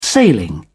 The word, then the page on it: sailing: British English